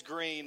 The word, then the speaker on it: Green: My name is green like the color Okay, it's, uh, it seems self explanatory, you would think.